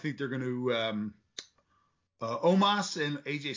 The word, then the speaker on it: Omos: I've been mispronouncing his name this whole time and no one corrected me. It's Omos. – You have? – Omos. – I've been saying almost.